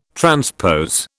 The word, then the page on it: transpose: British English